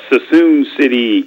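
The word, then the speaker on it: Suisun City: Susan City, California. – I knew I was saying it wrong.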